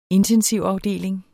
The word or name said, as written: intensivafdeling